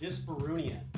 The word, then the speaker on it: dyspareunia: It's pronounced dyspareunia.